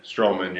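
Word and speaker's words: Strowman: Is it Stroman? – Stroman, yeah.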